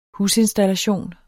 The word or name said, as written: husinstallation